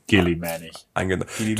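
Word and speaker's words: Guilliman: Du hattest in Deutschland, glaube ich, auch eine andere Aussprache als im Internationalen. Das haben sie irgendwie beim Übersetzen verbaselt. – Robot-Girlyman. – Nur wurde wirklich Guillaume ausgesprochen. Okay. In Deutschland. International Gilliman.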